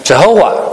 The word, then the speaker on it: Jehovah: 那原来没有这个呢，应该我们如果我们按照我们这种理解读呢，应该叫亚威，但是呢加上阿多奈这个母音呢就变成了 Jehovah。